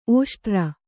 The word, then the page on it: ಓಷ್ಟ್ರ: ōṣtra